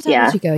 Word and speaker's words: Topo Chico: I'm trying Topo Chico. Is that how you say it? I have some of that. – Oh, I love Topo Chico. Yeah.